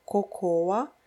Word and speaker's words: cocoa: Это также «какао», которое пишется «cocoa», и, может быть, его и хочется так произнести, но нужно его произнести «coco».